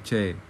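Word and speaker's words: Choe (최): Kalau secara Roman bacanya Choi ya. Choi. Tapi kalau secara Hangul bacanya Choe.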